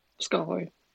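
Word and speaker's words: Skårhøj: Ähm, Skerhoy? Heißt das so? – Skerhoy. – Skerhoy, okay. Skerhoy.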